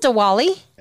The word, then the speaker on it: Diwali: Is it pronounced Diwali? – Yes.